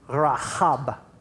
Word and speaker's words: Rahab: Now, I think that we all can relate to Rahab. – That was incredible. – That's the authorized way to pronounce her name.